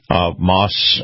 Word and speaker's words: Maus: Moss is spelled M-A-U-S, Family Automotive.